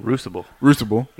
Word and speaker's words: Crucible: Crucible.